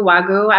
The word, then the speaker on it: Wagyu: So as far as diet goes, I just discovered, okay, I knew of Wagyu, I don't know if I'm saying it correctly, steak.